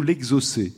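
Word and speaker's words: exocet: L'exocète. – Alors, c'est vrai que ça s'écrit l'exocète, mais j'avais eu la question une fois et on m'avait fait remarquer qu'on disait, paraît-il, plutôt l'exocé. Mais c'est vrai qu'on...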